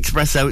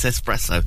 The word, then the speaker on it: espresso: It's not espresso, it's espresso.